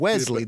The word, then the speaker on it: Wesley: Wesley. There's no T.